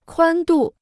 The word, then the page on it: 宽度: kuān dù